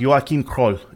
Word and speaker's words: Joachim Kroll: And in German, his name would be pronounced Joachim Kroll. Joachim Kroll. In English, maybe Joachim, but Joachim Kroll. So J-O-A-C-H-I-M, if you want to look him up. Last name Kroll, K-R-O-L-L.